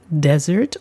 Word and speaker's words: desert: Der Unterschied liegt in der Betonung. Desert mit Betonung auf der ersten Silbe und einem S bedeutet Wüste oder verlassen.